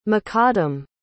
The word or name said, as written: makadam